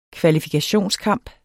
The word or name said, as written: kvalifikationskamp